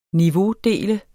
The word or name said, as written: niveaudele